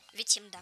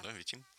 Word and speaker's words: Витим: А у меня вот Витим, крупный левый приток Лены. – Витим, где вы? Вы в России. – Я правильно ударение поставил? – Витим, да. – Да, Витим.